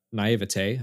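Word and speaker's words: naïveté: Like, we're using this thing and we don't fully understand how it works, which is another instance of naivete, I guess. Did I say it right that time? – Yeah.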